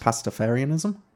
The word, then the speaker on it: Pastafarianism: So Pastafarianism is that the right amount of syllables? – Pastafarianism.